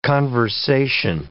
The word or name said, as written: conversation